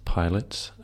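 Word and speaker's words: PLTS: This is it's pronounced Pilots, based in Byron Bay in Australia.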